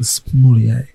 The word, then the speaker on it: sommelier: A sommelier. – That is shocking. – Shocking that I pronounced that correctly and not earthquake.